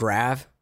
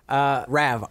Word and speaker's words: Rav: Rav, R-A-V. That's my new real name. – Like the car, like the Rav4. – Just like that.